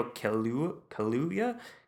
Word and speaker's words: Kaluuya: The one that does stand out, and forgive me for mispronouncing his name because I know I'm going to, is Daniel Kaluuya. That's how you pronounce it.